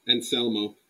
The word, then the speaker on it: Anselmo: What do we think about the new Phil Anselmo? Alsmo? – Anselmo. – That's not how you say it.